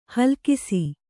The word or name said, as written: ಹೇಲುಕಸ